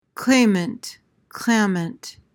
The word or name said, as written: clamant